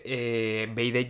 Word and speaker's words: BYD: Y siempre BID, para quienes mirábamos cuáles son los principales fabricantes, BID lleva BYD, que se escribe, no B y latina y D.